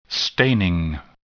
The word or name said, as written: staining